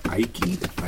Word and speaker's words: Aiki: And then from Genji, it goes to Ayaki. Aiki, right? A-I-K-I, is that right? – Mm-hmm.